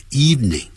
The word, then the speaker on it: evening: 好，这个就晓得了。我们在上一次讲的在早上下午晚上呢，我们都要用 in the morning in the afternoon in the evening，别忘了不要念成 evening evening，只有两个音节 evening 啊，这样念实际上是这样。